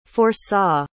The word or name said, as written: foresaw